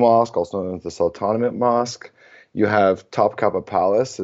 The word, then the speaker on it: Sultanahmet: Firstly, to start off, I think everyone needs to be looking at the Sultanahmet neighborhood. That's mm-hmm. how you say it colloquially, but it's named after the Sultan Ahmet. So it's literally Sultan Ahmet.